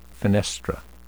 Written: fenestra

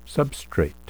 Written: substrate

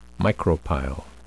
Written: micropyle

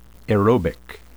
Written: aerobic

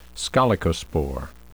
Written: scolecospore

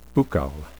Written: buccal

